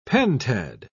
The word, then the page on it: pentad: British English